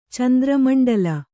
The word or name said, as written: ಚಂದ್ರಮಂಡಲ